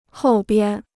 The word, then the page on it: 后边: hòu bian